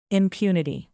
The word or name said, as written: impunity